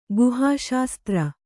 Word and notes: ಗುಹಾಶಾಸ್ತ್ರ: Kannada

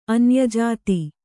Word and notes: ಅನ್ಯಜಾತ: anyajāta

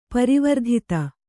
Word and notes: ಪರಿವರ್ಧಿತ: parivardhita

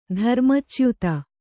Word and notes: ಧರ್ಮಚ್ಯುತ: dharma cyuta

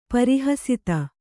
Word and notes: ಪರಿಹಸಿತ: pari hasita

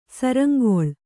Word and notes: ಶೃಂಖಲ: śřnkhala

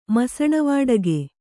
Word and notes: ಮಸಣವಾಡಗೆ: kannada

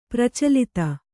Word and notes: ಪ್ರಚಲಿತ: pracalita

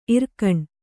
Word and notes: ಇರ್ಗೇಣ್: irgēn

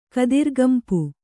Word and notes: ಕತ್ತುರಿಗಂಪು: kannada

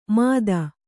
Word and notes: ಮಾದ: Kannada

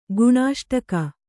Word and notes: ಗುಣಾಷ್ಟಕ: Kannada

